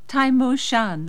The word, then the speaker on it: Tai Mo Shan: Tai Mo Shan, that's the name of the mountain.